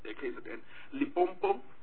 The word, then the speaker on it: Limpopo: In Limpopo, sometimes we don't pronounce it correctly. It's Limpopo.